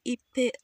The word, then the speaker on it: Ikpe: Nse Ikpe Etim, I think. – What Because K and P if, becomes like uh, a a strong B sound almost.